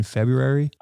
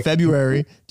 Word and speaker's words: February: It's like, do you pronounce the first R in February? Probably not, because Girthite borrows it. – Oh my God. Yep. – Okay. So February, do you say that first R?